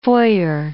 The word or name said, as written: foyer